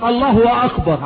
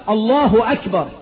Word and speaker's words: الله أكبر: واحد بيقولها غلط يقولك الله هو اكبر، عملهم اثنين الله هو اكبر، لا طب ده الهاء مضمومة، هو اشبع هاء لفظ الجلالة بالضم فقلب الضم الى واو، مش الله هو أكبر، الله أكبر، الله هو.